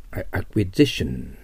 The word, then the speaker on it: acquisition: acquisition アクセント変わります。